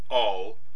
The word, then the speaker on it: al: Guadalstab AL, pronounced all, refers to a location in a general way.